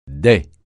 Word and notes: dais: francais

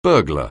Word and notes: burglar: anglais

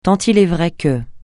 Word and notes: tant il est vrai que: francais

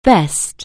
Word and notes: bespoke: anglais

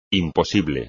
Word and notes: imposible: espagnol

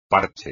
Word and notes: parche: espagnol

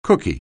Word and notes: cookie: anglais

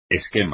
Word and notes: esquema: espagnol

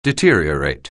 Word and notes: deteriorate: anglais

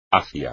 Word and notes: hacia: espagnol

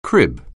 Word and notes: crib: anglais

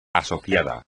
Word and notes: asociado: espagnol

